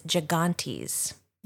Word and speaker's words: gigantes: These giants were known as gigantes. – Yeah, and this word, it's spelled G-I-G-A-N-T-E-S, which I think we're kind of going with the Greek pronunciation here.